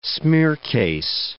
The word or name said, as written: smearcase